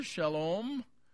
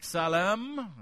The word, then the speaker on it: Salem: Salem, of course, would be pronounced in Hebrew, Shalom. In Arabic, Salem. I've heard it both ways over there.